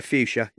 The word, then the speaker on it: Fuchsia: And just trying to figure out where Fuchsia, not sure if I'm saying that right. Anyway. – Yeah, Fuchsia, yeah.